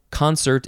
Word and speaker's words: concert: Second form of concert is actually pronounced concert. Concert.